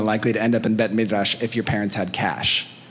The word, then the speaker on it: Bet Midrash: Can you say that? Bet Midrash. You can't do it. You guys are scared. Ready? One, two, three. Bet Midrash. You have to roll your R.